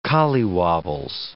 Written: collywobbles

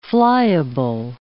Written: flyable

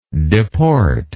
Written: deporter

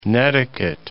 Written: netiquette

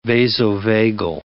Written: vasovagal

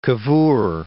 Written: Cavour

